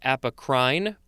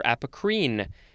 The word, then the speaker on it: apocrine: C-A-P-O-N. Next we have apocrine, could also be apocrine or apocrine. It is spelled A-P-O-C-R-I-N-E.